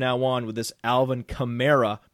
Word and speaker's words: Kamara: Kamara is the correct pronunciation.